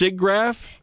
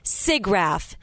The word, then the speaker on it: SIGGRAPH: Pronounced SIGGRAPH? – SIGGRAPH.